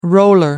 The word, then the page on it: roller: American English